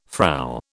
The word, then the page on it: Frau: British English